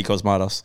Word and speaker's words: Cosmatos: Cosmatos? – Yeah, George P. Cosmatos.